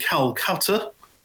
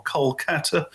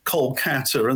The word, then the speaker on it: Kolkata: So it was, I played in Calcutta, if I give it the English pronunciation, or Kolkata, give it the modern pronunciation. Although sometimes with these names, you do wonder, because you're there and, you know, they're kind of insisting on Kolkata, and then you get a newspaper pushed under your door and it's the Calcutta Times.